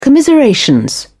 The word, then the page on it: commiseration: British English